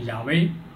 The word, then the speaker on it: 亚威: 这里让我们看到耶和华一词，在希伯来文的读音中，比较接近中文的读法呢，就是亚威。